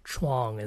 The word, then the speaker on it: Chuang: But in any case, oftentimes I write it out, even though my name is technically one syllable. Chuang is one syllable. I spell it out so that for people to pronounce it correctly, at least in English, because it sounds different in Mandarin or Taiwanese, of course, that I break it down to two syllables. Chu, like C-H-O-O, and Wong, W-O-N-G.